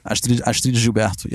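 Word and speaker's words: Astrud Gilberto: And correct me on the pronunciation of the last name. Astrid Gilberto? – Astrid Gilberto, yeah.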